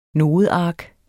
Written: nodeark

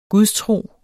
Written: gudstro

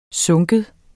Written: synke

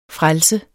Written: frelser

